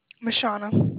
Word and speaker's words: Mishauna: Mashana. – Mashana, nice to meet you. How do you spell that? – So, M-I-S-H-A-U-N-A.